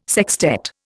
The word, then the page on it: sextet: Indian